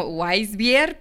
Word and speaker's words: Weissbier: Weissbier. – Que se escribe como Weissbier, pero pues en realidad si tú quieres llegar muy conocedor a la tienda, llegas y le dices, me das una Weissbier.